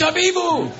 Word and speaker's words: Habibu: And I played a part in that, an Arab guy named Prince Habibu, who everybody pronounces his name wrong, like heebie-jeebie. It's Habibu!